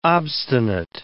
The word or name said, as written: obstinate